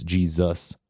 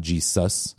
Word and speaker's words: Jesus: And then the final two things that happened is that the S migrated in a lot of people's pronunciations to a Z sound, right? It's Jesus, not Jesus. – Mm. – And the emphasis moved from Jesus to Jesus.